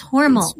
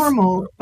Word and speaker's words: Hormel: And we need to say it right. Is it it's Hormel. – It's Hormel. Hormel. Okay, yes. Hormel. – Yes. Uh-huh. Although most of us know it as Hormel. – But it's Hormel. – As I understand from everybody in Austin, the family pronounces it Hormel.